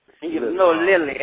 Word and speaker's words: Lilly: Lily, L-I-L-L-Y.